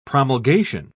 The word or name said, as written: promulgation